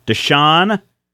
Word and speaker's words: Deschain: Deschain? How do you say that? – Deschain.